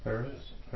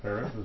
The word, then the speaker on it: Peretz: Really, how do you say that? – Peretz. Peretz is right, yeah. Well, in Hebrew, you might throw some more phlegm in there.